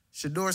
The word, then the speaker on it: Shedeur: Shedeur Sanders.